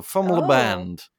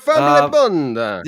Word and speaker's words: FMLYBND: The song is called Phoenix, and it's from, I guess you're supposed to pronounce this Family Band, but it's spelled FMLYBND. So Fumlaband. Fumlaband. – We're a family bund.